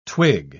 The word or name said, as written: twig